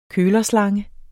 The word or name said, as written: kølerslange